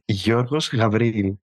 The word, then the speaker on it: Giorgos Gavriil: It's Giorgos Gavriil.